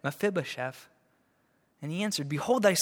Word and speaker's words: Mephibosheth: Did I say Methuselah? Oh man, I'm sorry. Mephibosheth. Thank you. You guys are the best. Mephibosheth, the son of Jonathan, the son of Saul, was come unto David.